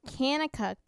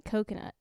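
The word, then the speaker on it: Kanakuk: Well, it's actually pronounced Camp Canicuk, but if you'd like to pronounce it wrong, you can pronounce it Coconut.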